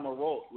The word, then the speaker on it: Marolt: Snapchat, it's Al Marolt, which is my actual last name, Al_MAROLT.